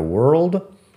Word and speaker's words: world: Hay muchas palabras que acortamos un poco, nos comemos una consonante en, en una combinación así, pero world se pronuncia world, RLD, world, world. fíjate que la O tampoco es como O, es E world y que la W es la misma W de wood.